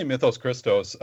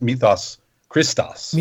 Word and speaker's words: Mythos Christos: You know, I say Mythos Christos. Um, I guess if we were going to go Greek, we would say mythos, mythos Christos.